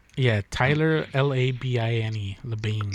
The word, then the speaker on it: Labine: Taylor L-I-B-I-N. Labine. Fuck, that's not even how you spell it. I'm an asshole. Shit, did I say Taylor? I think his name is Tyler. Fuck. I even wrote it down and I fucked it up. That's Dizzy. – Labine. – Hold on. He's Canadian, so it's spelled stupid. Yeah, it's Tyler Labine.